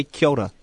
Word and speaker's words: kia ora: We say kia ora. – Kia ora. – Because that's the official that's the proper pronunciation.